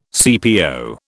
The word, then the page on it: CPO: British English